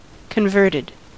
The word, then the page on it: converted: American English